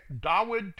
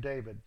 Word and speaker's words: Dawid: Verse 23, and it was when the spirit from Elohim was upon Shaul that Dawid took a lyre. It's hard for me to quit saying David. Even I, with all the studies I've done, fall back upon that wrong pronunciation.